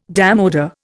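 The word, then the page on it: démodé: Indian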